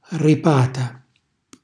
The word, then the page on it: ripata: calabrese